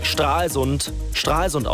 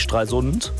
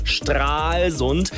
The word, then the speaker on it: Stralsund: Und falls Sie sowas nicht wissen, wie die Tatsache, dass Stralsund ausgesprochen wird und nicht Stralsund, merken Sie sich einfach langes A, wie beim Aal, Stralsund, hätten wir noch mehr.